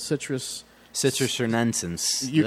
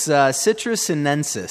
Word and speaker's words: Citrus sinensis: So that's why they call this one the Citrus Sinensis. I cannot say it either. – I'm not even trying to pronounce that. – It's Citrus Sinensis.